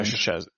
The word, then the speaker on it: Szczesny: Is that how you pronounce his name, Mr. Poland? Yeah, Szczesny. Yeah, I think Szczesny. – Szczesny. Right. Thank you.